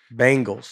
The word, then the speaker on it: Bengals: I say bangles, like the bangs on your hair. – Bangles. – But like the band, the Bengals. – Yeah, that's a funny reference. – I would like to say. Yeah. – But I mean, they spell it differently. – Both ways that Bengals, the way we say it is B-I, the way you say it is B-A.